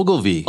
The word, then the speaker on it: Ogilvy: Ogilvy? O-G-I-L-V-Y. – Oh, Ogilvy.